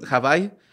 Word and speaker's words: Hawái: En realidad se pronuncia Hawái.